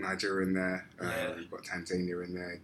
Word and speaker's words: Tanzania: How do you say it, George? – Tanzania.